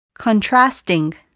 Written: contrasting

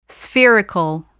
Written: spherical

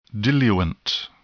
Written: diluent

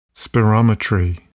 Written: spirometry

